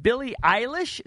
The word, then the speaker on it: Billie Eilish: Is it Billie Eilish? Is yes.